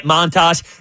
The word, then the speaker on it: Montas: Yeah, so they get Montas. That's how you say it, right? – Montas. – Frankie Montas.